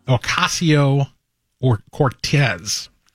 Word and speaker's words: Ocasio-Cortez: He was defeated by Alexandria Ocasio-Cortez. I'm learning how to pronounce this. – That is the correct pronunciation.